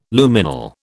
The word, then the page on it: Luminal: British English